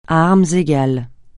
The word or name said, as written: à armes égales